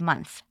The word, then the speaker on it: month: Let's pay attention to the pronunciation of month. It ends with th, and that sound might be tricky. Repeat month. Your tongue, lingua, has to come out a little bit, okay? Put it between your teeth, dentes.